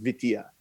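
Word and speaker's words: vitilla: I think it's like V-I-T-I-L-L-A, I think, but it's pronounced Vitia, and it's a Dominican street game.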